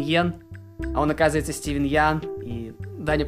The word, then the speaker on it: Ян: И в одном из шоу пару лет назад э, Стивен Ян сказал, что вы все неправильно произносите мою фамилию, я как бы я Ян.